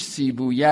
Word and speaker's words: سیبویه: کلمه سیبه وی هم تلفظ فارسیش سیبویه است، که وقتی این کلمات شیرویه و بابویه و سیبویه و اینا میره به عربی میشه بابوی، شیروی، سیب وعی.